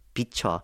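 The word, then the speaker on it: VCHA: That's V-C-H-A, but I believe in Korean it's pronounced a Bicho, meaning to shine upon.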